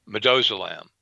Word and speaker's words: midazolam: There was a giant scandal in in uh, UK about I think the pronunciation is midazolam, which is the sedative drug used with uh, the ventilator treatment.